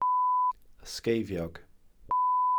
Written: ysgeifiog

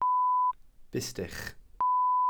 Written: bustych

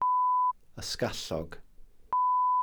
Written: ysgallog

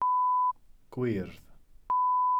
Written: gwyrdd

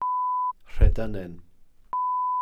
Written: rhedynen

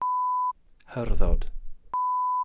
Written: hyrddod

